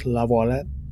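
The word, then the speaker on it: LaViolette: I'm going to pronounce that and be very pretentious. Lavoilette.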